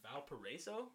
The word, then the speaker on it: Valparaiso: I don't know if I'm saying this right. Valparaiso? – Yeah. – Oh, shit, I said it right.